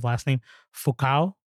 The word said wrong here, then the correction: Fuqua: Anthony Fuqua. – Fuqua.